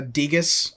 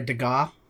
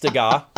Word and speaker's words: Degas: It is properly pronounced Degas uh, for the record. So um, I I hate to say that you might get some some flack back on that if you were to pronounce it that way.